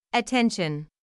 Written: attention

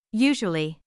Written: usually

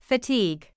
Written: fatigue

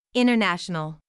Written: international

